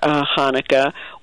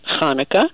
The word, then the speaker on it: Hanukkah: But the problem is that a chet has no comparable sound in English. And so people come up with all kinds of spellings depending on how they themselves pronounce Hanukkah. And if they use the chet sound all the time, they often will write a ch at the beginning of Hanukkah.